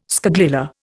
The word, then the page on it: scagliola: Indian